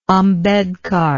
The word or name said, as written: ambedkar